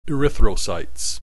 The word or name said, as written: erythrocytes